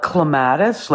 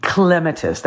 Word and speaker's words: clematis: Okay, just to be clear, most of us call it clematis, like clemato juice. So, but the proper name, way to pronounce it is clematis.